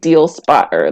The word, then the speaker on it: Dealspotr: It's the website is called Deal Spotter, but the way that it's spelled is D E A L S P O T R. So it's like Deal Spotter, like how I said it.